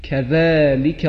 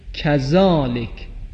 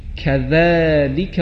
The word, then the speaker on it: كذلك: وقتی میخواییم ذکر قنوت رو بخوانی، دست ها رو که جلوی صورتمون میگیریم، بعد از سوره اخلاص میگوییم كذلك الله ربی، كذلك. این هم حرف ذال هست. لذا اگر کسی در نماز مثلا بگوید كذلك، كذلك، یعنی مثل زا تلفظ کنه، این درست نیست. باید بخوانیم كذلك الله ربی.